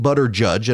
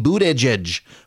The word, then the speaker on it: Buttigieg: I've had to finally go in and train Siri that, no, if I want you to text somebody about Pete Buttigieg, it's actually Pete Buttigieg, not Pete Boot Edge or Pete Butter Judge. And originally it was Pete Butter Judge, and then it became Pete Boot Edge. Well, that's how you pronounce his name, Boot Edge.